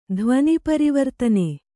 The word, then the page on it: ಧ್ವನಿಪರಿವರ್ತನೆ: dhvani parivartane